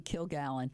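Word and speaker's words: Kilgallen: Yeah, those of us who remember Dorothy Killigan on shows... no, that's how you pronounce it.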